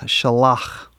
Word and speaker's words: shalach: The actual word send me is a Hebrew word that is pronounced shalach, and that's S-H-A-L-A-C-H.